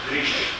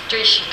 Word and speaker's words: Driesch: Is it Driesch? – Driesch. – Driesch, good.